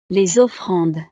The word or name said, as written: les offrandes